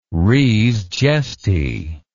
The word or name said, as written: res gestae